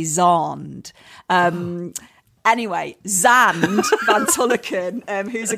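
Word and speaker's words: Xand: So my friend Zan van Tulleken, who is actually Zand van Tulleken, but I can't pronounce his name Zand because of my accent.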